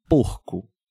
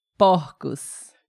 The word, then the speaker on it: porcos: Porcos. Perceberam a diferença, pessoal, de pronúncia? No singular, essas palavras, no singular, um pouquinho mais fechado. Porco. E no plural, porcos. Porcos.